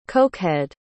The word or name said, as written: cokehead